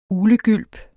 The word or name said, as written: UGLEGYLP